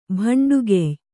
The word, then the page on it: ಭಂಡುಗೆಯ್: kannada